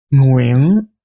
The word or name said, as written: Nguyen